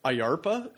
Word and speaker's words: IARPA: There's a group that in this article I had never heard of before until reading this, and it's pronounced IARPA, and it stands for Intelligence Advanced Research Projects Activity.